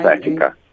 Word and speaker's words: sciatica: And does it treat sciatica? It's, I don't know what, if I'm saying it correctly. SCI? – Yeah, sciatica.